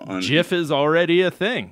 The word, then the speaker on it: GIF: But he was just out there saying, like, it should be pronounced GIF, like the peanut butter, rather than a hard G.